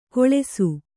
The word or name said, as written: ಕೊಳೆಸು